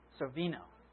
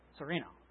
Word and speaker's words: Sorvino: Is that right, Mira? Sorvino. Sorvino. Okay.